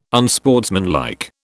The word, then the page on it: unsportsmanlike: British English